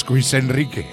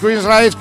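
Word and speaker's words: Queensrÿche: a los Queensryche. O como les llama mi amigo Scotty Ternasco, los Quis Enrique. Bueno, pues los Queensryche que acaban de editar su decimosexto álbum de estudio.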